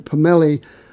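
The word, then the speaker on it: pommele: And this is a very beautiful looking dreadnought, and it's got what they call pommel sepele back and sides, and a bear claw spruce top. Now, pommel is the anglicized pronunciation of the French word pomelle, or pomelle, that basically means dappled.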